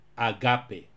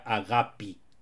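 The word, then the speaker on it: agapi (ἀγάπη): agape. En griego moderno se pronuncia agapi.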